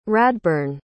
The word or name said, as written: radbyrne